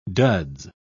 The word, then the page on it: duds: British English